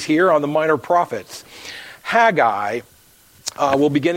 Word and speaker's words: Haggai: I encourage you to open your Bibles to Haggai, not Haggai, it's just two syllables, Haggai.